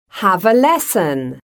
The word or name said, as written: have a lesson